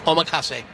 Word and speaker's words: omakase: Omakase.